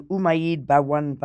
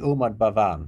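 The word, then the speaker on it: Umaid Bhawan: Now, this palace is beautiful, the Umayyad Bhawan Palace. – It's pronounced the Umayyad Bhawan. – Of course, I couldn't say it correctly.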